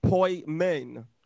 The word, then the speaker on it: poimen: It's a Greek word, poimen, P-O-I, it's pronounced poimen.